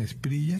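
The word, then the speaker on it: Espriella: Esprilla se escribe, pero ellos pronuncian Esprilla.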